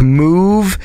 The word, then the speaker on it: Mouv: I think this is pronounced Electric Move, MOUV. Hope that's Electric Move.